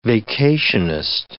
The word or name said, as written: vacationist